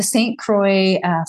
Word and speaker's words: St. Croix: Is it St. Croix? – Yeah. The St. Croix, 40 miler winter ultra.